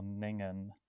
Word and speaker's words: Ningen: And it's called, well, it's spelt N-I-N-G-E-N. So I'm assuming it's pronounced Ningen or Ningen. And it's described as a whale-like creature. – It's a Japanese word. It would be pronounced Ningen.